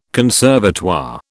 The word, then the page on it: conservatoire: British English